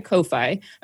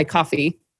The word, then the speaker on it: Ko-fi: So basically through my Ko-fi or my coffee.